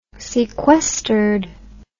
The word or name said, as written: sequestered